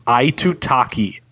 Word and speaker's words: Aitutaki: Aitutaki. Aitutaki.